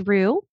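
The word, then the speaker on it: Roux: There's Rue, that's R-O-U-X. So it's French for redhead. It's like a French slang term for a redhead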